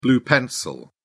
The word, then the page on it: blue-pencil: British English